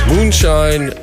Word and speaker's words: Muneshine: The artist is Moonshine. It's spelled M-U-N-E-S-H-I-N-E.